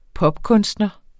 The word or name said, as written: popkunstner